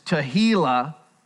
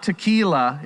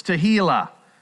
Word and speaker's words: Tehillah: It's Tehillah, okay? Not tequila, it's Tehillah. You take a little tequila, you might be clamorously foolish, but it won't be for God. But this is Tehillah, and it means to sing a song of thanksgiving to God.